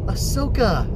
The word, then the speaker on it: Ahsoka: They're talking about Ozaka? Is that his name? That's kind of an interesting name. It sounds a little Japanese to me. I think that's really neat. Turns out that people were mispronouncing Ahsoka, as in Ahsoka Tano, yes!